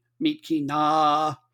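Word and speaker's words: Myitkyina: Kachin United, they play out of the city of Mitkina. And I think I stretched out the pronunciation long enough. I believe in that language that it's important to the vowel length means something. Mitkina.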